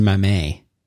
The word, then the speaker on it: Mamet: Well, you know David Mamet did the Glenn Ross. – I think it's pronounced Mamet.